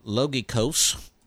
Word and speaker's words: logikos: There is a Greek word that's derived from the same logos word, the Greek word logikos, which sounds like it rhymes with logical, and that's what it means.